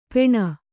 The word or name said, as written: ಪೆಣ